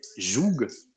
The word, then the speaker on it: joug: le mot en français joug, le joug donc euh, qu'on prononce jou, mais qui, qui s'écrit J-O-U-G.